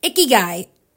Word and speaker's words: Ikigai: The Ikagi book came up. Hopefully I said that right. Ikigai. Yeah. I knew I'd say it wrong, but I'm trying.